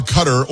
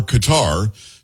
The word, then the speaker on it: Qatar: He's in Doha, Qatar, or Qatar, depending on how you like to pronounce it, but that we've got him coming up hour number two and hour number three.